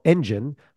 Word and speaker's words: ENDGN: Our guest today, Aaron Steele, is the founder and CEO of Engine, which is spelled E-N-D-G-N, but sounds like a car engine or a growth engine, if you want, is a content lead generation ninja.